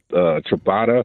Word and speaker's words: ciabatta: Great, great bread, especially the ciabatta, if I'm saying that right, Robert.